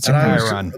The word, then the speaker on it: Chiron: Chiron.